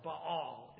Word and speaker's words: Baal: So in the Old Testament, we'd know the name Baal, or Baal, if you want to pronounce it more Hebrew style.